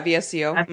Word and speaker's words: VSCO: I used, do you guys know like the app, I think you pronounce it like VSCO? – Yeah, VSCO.